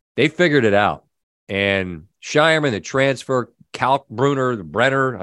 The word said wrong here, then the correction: Kalkbrenner: I always get his name wrong. – Kalkbrenner, he's got such great hands.